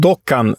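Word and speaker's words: Doğukan: Och en spelare som vi ska hålla koll på, det är, det stavas ju Dogukan, hans förnamn, men som jag förstått uttalas han Dokan Sinik.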